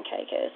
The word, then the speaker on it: Caicos: Turk's head cactus in Caicos is the Lucayan term for Cayahico, meaning string of islands.